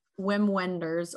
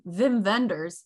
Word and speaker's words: Wim Wenders: His name is Wim Wenders, or if you're German, Wim Wenders. – Wim Wenders. – Yeah. Wim Wenders. That's the German pronunciation.